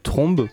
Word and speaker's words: tromba: En fait, trombe, parce que ça s'écrit tromba, mais ça se dit trombe.